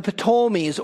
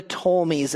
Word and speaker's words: Ptolemies: And the kings of the south are the kings of Egypt and the Ptolemies, or the Ptolemies, as some say. I say the P. There's no necessarily right or wrong pronunciation.